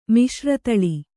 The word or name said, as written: ಮಿಶ್ರತಳಿ